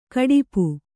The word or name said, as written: ಘಾಟುಪ್ಪು